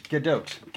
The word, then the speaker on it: Gadot: Is it Gadot? – Gadot. Gal Gadot, because she is Israeli, she is not French.